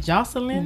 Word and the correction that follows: Joycelyn Savage: Joycelyn Savage. – Joycelyn Savage.